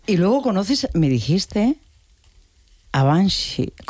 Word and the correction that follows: Banksy: Y se pronuncia, en España la gente lo dice de otra manera, ¿no? Pero bueno, como Banksy. – Esas palabras. Es Banksy. – Banksy.